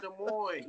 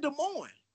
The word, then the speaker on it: Des Moines: And that in the way it's pronounced ain't even the way it's spelled. It's Des Moines with an S. But you're going to take all the S's off and whatever, man.